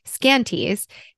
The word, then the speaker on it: Skanties: And I know like technology is getting better, but one thing that you talk about in the context of your brand, Skanties, is that I'm pronouncing that correctly, right? Yep, okay, great.